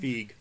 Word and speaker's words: Feig: Feig.